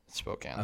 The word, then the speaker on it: Spokane: I think is it Spokane? – Spokane.